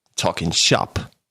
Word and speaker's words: Talking Shop: Talking Shop. Also Shop ist die Betonung.